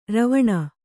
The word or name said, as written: ರವಣ